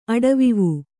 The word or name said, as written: ಅಡವಿವೂ